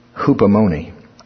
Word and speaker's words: hupomone: I'll spell it for you. Hupomone. Hupomone. That's how you say it, according to this. Strong's. Hupomone.